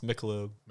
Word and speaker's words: Michelob: It's Michelob.